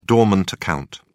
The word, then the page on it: dormant account: British English